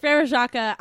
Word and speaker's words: Frère Jacques: Frère Jacques? – Frère Jacques.